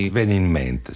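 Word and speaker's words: S'at ven in ment: Sì, vi faccio ascoltare adesso un brano di un album che si chiama Sat Ven in Ment, e non so se lo so pronunciare bene, perché Sat Ven in Ment, sì. – Sì, è in modena, in sassolese anzi, ed è un album del 1978.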